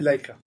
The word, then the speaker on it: Leica: Leica.